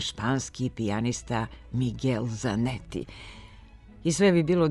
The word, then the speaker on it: Miguel: Ali s izvorno španskim imenom Miguel, koje se bez izuzetka na srpskom izgovara Miguel, i kad je portugalsko, godinama imamo teškoće. Model prema kojem bi se izgovor ovog imena mogao zapamtiti je ime slavnog španskog pisca Don Quixota, Miguel de Cervantes, potom ime bivšeg čuvenog španskog bicikliste Miguel Indurain, pa Miguel de Unamuno, kako se zvao španski pisac i filozof.